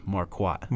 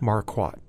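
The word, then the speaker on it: Marquard: So, Rube Marcotte. – It's actually pronounced Marquat. – Marquat, okay.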